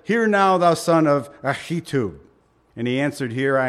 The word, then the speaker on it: Ahitub: Actually, this is pronounced Ahitub, believe it or not. That's the real Hebrew. So you got to like spit a little on your Bible when you say this. Ahitub.